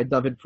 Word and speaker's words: Dovid: I would recommend that you, the listener, hop on over to LinkedIn and type in David's name, which is D-O-V-I-D. He pronounces it David.